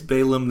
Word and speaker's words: Balaam: She doesn't want her brothers, who we find out are Balaam and Titus. Balaam? – It's Balaam. They mispronounced it.